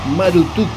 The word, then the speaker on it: Marduk: De hecho, los estudiosos creen que la pronunciación correcta de Marduk era Marutuk,